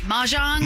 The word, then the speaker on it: mahjong: Mahjong, am I pronouncing that right? – Yeah. – Mahjong? – Mahjong, I think.